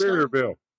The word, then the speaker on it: Sevierville: Sevierville. I think it's Sevierville, probably.